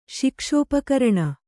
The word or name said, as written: ಶಿಕ್ಷೋಪಕರಣ